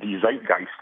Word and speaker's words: zeitgeist: So what people end up coming up with is something that is new that fits the zeitgeist, all right, the cultural spirit, if I'm pronouncing it that right. – That's right.